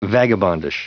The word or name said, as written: vagabondish